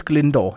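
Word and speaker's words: Glyndŵr: Altså, hans fornavn staves også Glyndwr. – Okay. – Ja, udtales Glendore. Jeg har googlet det.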